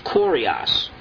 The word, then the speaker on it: kurios: Now the word there for Lord is kurios. K-U-R-I-O-S, the way we would spell it, kurios in the Greek.